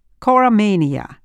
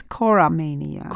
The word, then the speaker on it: choromania: I would pronounce this choromania. – Choromania.